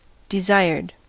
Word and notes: desired: American English

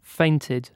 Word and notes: fainted: British English

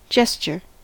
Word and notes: gesture: American English

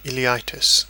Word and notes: ileitis: British English

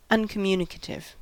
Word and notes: uncommunicative: American English

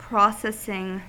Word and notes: processing: American English

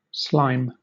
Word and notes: slime: British English

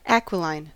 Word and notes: aquiline: American English

